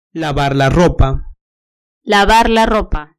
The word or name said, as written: lavar la ropa